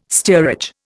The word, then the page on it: steerage: Indian